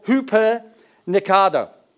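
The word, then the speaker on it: hupernikado (ὑπερνικάω): In the Greek, that word, and I'm going to try and pronounce this, it's called hupernikado. Hupernikado.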